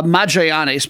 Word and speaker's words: Magallanes: Now, it's possible it's uh, Magallanes because I know the club is named for uh, Magellan the Explorer, but I think it's usually a hard G.